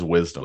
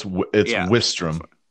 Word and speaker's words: Grant Wistrom: I always thought it was Wisdom. – Wisdom. – It's Wistrom.